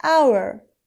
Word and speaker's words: hour: مثلا an hour یعنی یک ساعت. H در این کلمه تلفظ نمیشه، پس آبای اولان صدا داره.